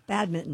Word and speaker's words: badminton: You don't pronounce that N? – You do not. – No, badminton.